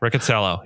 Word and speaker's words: Riccitiello: So it's Riccitiello. Is that how you say it? – Yes. – Okay. Riccitiello.